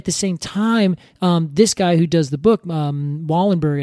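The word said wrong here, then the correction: Wohlleben: He's a German man, and I keep on saying his name wrong. – Peter Wallenben. – Wallenben, that's it.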